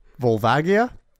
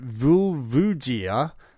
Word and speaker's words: Volvagia: Volvagia. And then he says, I pronounce it Vulvugia and have done since Ocarina of Time's original release.